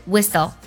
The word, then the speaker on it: whistle: t 不发音，像城堡 castle，还有口哨 whistle，都是这样的。